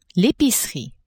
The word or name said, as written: epicerie